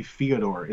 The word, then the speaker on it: Fyodor: Or rather, it's supposed to be Fyodor, isn't it? We've been mispronouncing his name his entire career, and now we can't stop.